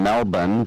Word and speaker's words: Melbourne: It'll be the Rams and Niners from the MCG in Melbourne. Don't pronounce the R.